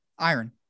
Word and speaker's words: iron: Well, you're really not supposed to pronounce the R that hard. It's iron.